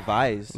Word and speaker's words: Weisse: I know they say Weisse. – Weisse? – Well, because in Germany, you pronounce a W like a V.